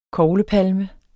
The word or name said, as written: koglepalme